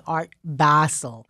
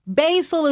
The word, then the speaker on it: Basel: And it is so important for people to pronounce it correctly because there is no better way to prove your ignorance and uncouthness than saying Art Basel. Basil is what you make pesto out of.